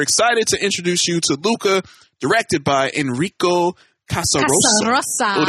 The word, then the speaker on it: Luca: Am I saying that right? Is it Luca? – Yes, Luca. – Luca. All right, cool.